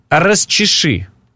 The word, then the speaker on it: расчеши: А знаешь, как говорят москвичи? – Как правильно говорить? Расчеши.